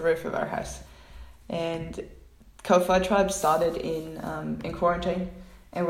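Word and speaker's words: kōwhai: I hope that I say this correctly, but in Māori, W and an H is pronounced an F, I think. So it's Ko-Fi.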